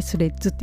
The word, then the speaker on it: Threads: の綴りが THREADS ですね THREADS でスレッズって読むんですけど、